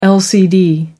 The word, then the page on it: lcd: American English